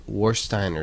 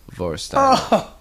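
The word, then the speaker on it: Warsteiner: He asked me what I was drinking, and I was like, I said, Warstein or Dunkel. He's like, you know it's pronounced Vorstein. And I was like, you know, I am aware of that, but I am speaking English, not German. I didn't say that, but that's what I was Oh my God.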